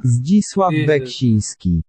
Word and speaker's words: Zdzisław Beksiński: Zdzisław Beksiński. – Ah, pille, se pronuncia Zdzisław Beksiński. Así.